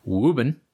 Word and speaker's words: Woburn: So in my 20s, I used to work in Woburn, Massachusetts, pronounced in the vernacular Woobin.